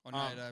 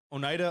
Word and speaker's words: Oneida: But Minocqua Brewery has been ordered and voted on to be shut down by, I'm going to say this wrong, Oneida County. – Oneida, I believe it is. – You have no idea. Oneida.